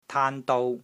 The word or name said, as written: TAAN1 DOU1